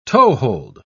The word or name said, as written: toehold